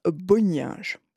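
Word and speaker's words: Bonynge: Chacun a sa prononciation, en fait. Moi, j'aime bien dire Bonninge.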